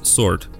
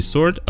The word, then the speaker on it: sword: Слово меч, оно у нас sword говорится. Но здесь есть W, который мы не произносим. То есть по факту это не sword, а sword. То есть там W есть.